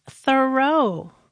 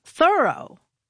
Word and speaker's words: Thoreau: All this time, I thought his name was pronounced Thoreau, but no. – How is it pronounced then? – Thoreau.